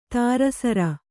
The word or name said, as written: ಧಾರಾಸಾರ